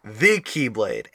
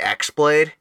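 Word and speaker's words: the Keyblade (χ-blade): So it's shown in game as the X Blade, but it is pronounced the Keyblade. Yes. Because it is like the symbol for key.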